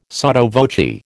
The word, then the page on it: sotto voce: American English